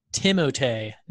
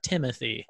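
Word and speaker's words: Timothée: I read an article this very day that said that his name is actually pronounced Timotei, not Timothy.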